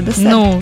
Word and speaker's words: десерт: Ні, це таке коварне, тому що наголос так і залишається десерт. Так, це правильно.